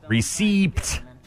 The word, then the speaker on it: receipt: So E-I-P-T. – E-C-E-I-P-T. Receipt. Receipt. And we don't even say it the way it's spelled. We say receipt. The second, the P there is just sort of there for good measure.